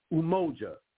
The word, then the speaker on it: Umoja: It's pronounced Umoja.